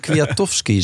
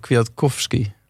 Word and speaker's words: Kwiatkowski: Maar het is toch Kwiatkowski? – Ja, dan weet ik niet. Gewoon slordig. – Een K achter een T spreek je nooit uit in de pols. Dat weet je toch? – Ik weet het niet.